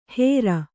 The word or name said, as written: ಹೆರ್